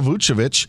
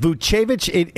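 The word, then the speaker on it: Vučević: Is it Vucevic? It's one of those names that has changed in pronunciation. – I know. – Several times. Vucevic, now back to Vucevic. – I'm going to go Vucevic on this.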